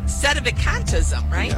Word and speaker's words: sedevacantism: Sedevacantism, right? – Yeah.